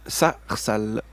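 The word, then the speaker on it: Saahsal: le groupe Sarsal. C'est juste, c'est comme ça qu'on prononce. C'est comme ça qu'on hein, prononce.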